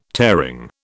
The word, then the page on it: tearing: British English